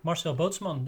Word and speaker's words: Marcel Bootsman: Marcel Bootsman, that's how I say it.